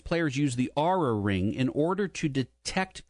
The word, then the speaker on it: Oura: The NBA is considering having its players use the, and we were wondering how you pronounce this. It's spelled, Luke spelled it O-U-R-A. – Yeah, Aura. – We're just going to say Aura. – It's Aura, that's right. – Aura, okay.